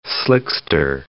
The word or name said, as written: slickster